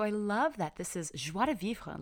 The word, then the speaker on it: joie de vivre: You guys have heard me talk about it before, but it's joie de vivre. It's spelled J-O-I-E, new word, D-E, And then the final word is V-I-V-R-E, joie de vivre.